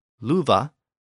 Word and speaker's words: lufa: So the original Old English version of the word love, luva, was spelled L-U-F-A. But again, the pronunciation was voiced with a V sound.